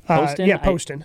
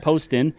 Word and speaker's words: Poston: Poston. – Poston.